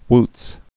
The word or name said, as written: wootz